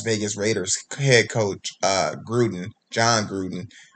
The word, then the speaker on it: Gruden: I think it's pronounced Gruden. – Okay. John Gruden.